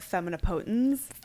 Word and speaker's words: Femina Potens: Also, is it pronounced Femina Potens? Femina Potens? – It can be. I always called it Femina Potens. – Femina Potens. – But different people called it different things. It's Latin. That's all I know.